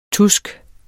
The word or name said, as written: TUSKS